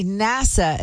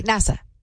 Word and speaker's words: NASA: Ken says, please say NASA again. You're saying NASA, like you want to go work in the Bahamas.